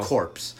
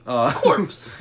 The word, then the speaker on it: corps: It's spelled corpse. – Corpse. – Corpse. I never understood that. – The corps? Yeah, it's corps. Medical corps. – Why don't they pronounce the P?